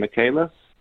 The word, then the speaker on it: Michaelis: I know my name will be on everything, but Cory, and then it's Michael, spelled like Michael normally is, I-S on the end, Michaelis,